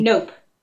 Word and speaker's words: Knope: Is it Nope or Knope? – Nope. – Nope. – Leslie Knope. – K-N-O-P-E. Leslie Knope.